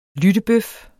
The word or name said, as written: lyttebøf